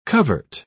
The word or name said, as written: covert